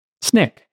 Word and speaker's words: Snyk: SNYK. S-N-Y-K. So the Y is an I. SNYK. And no C. S-N-Y-K.